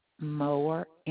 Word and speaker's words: Moore: Gayla, G-A-Y-L-A. – Your last name? – Moore, M-O-O-R-E.